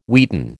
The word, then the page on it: wheaten: American English